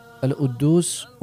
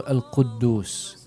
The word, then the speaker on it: Al-Quddus: Pa se često može čuti Allah umjesto Allah, Al-Uddus umjesto Al-Quddus, Ar-Reza umjesto Ar-Rezaq, a razlike su ogromne.